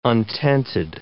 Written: untented

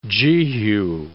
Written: jehu

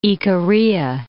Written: Icaria

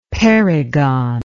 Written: perigon

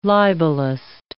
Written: libelist

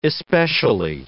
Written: especially

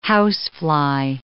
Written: housefly

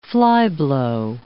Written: flyblow

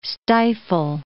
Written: stifle

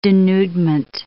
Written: denudement

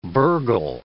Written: burgle

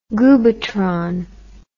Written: goobatron

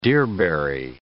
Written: deerberry

